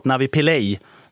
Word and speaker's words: Navi Pillay: Navi Pillay, ska vi uttala det.